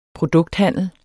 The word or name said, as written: produkthandel